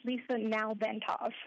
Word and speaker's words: Lisa Nalbantov: Lisa, how do you say your last name? – It's Lisa Nalbantov. If you break it up in three syllables, it's not so bad.